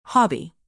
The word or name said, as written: hobby